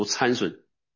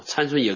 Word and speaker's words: 参孙: 哦，刚才我读的森孙是不是啊？是在英文发音就是森孙啊，中国人读参孙啊，参孙也可以啊。这个没有什么，它原文就是申孙，给大家稍微一个更正啊。